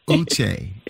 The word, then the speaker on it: Uché: So my first name is pronounced Uche, because I know if I have family members listening, they will be so upset that I didn't correct you. – Uché.